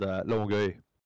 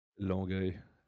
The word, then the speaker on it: Longueuil: Longueuil. – Not making up words. – It looks like you're saying long gay with like a bunch of peanut butter in your mouth. – It's spelled L-O-N-G-U-I-E-L.